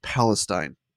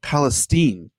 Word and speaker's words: Palestine: And by the way, it's not pronounced Palestine. I learned this yesterday. Being from Ohio, I actually learned this yesterday. – Okay. – It's pronounced Palestine.